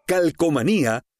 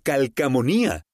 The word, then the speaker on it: calcomanía: ¿Cómo se dice correctamente? ¿Calcomanía o calcamonía? De acuerdo con la RAE, la forma correcta es calcomanía, con O, calcomanía.